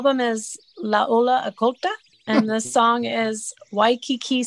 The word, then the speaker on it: La Ola Oculta: Say it right. You have to say it like a matador. La Ola Oculta.